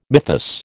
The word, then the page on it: mythus: American English